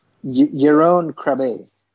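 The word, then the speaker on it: Jeroen Krabbé: Jerome Krabbé. – Jerome Krabbé. I'm never going to say it right again, so I said it right once, so we'll go with that.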